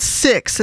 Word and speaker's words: CIX: That's Six, and that's spelled C-I-X, Save Me, Kill Me.